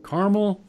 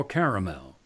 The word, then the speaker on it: caramel: Carmel or caramel? How do you pronounce it, Suzanne? – Carmel. – Carmel. You're with the majority, 64%.